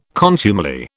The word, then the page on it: contumely: British English